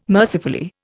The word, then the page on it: mercifully: Indian